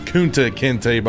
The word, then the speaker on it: Kunta Kinte: His name's pronounced Kunta Kinte, by the way. I don't want you to misconstrue it.